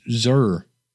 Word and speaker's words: Zehr: What, Jeremy, I guess it's pronounced Zer, Z-E-H-R.